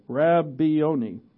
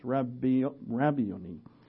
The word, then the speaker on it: Rabboni: I looked up how to pronounce it, and I almost just said ravioli. Rabbioni is how it's pronounced. Rabbioni.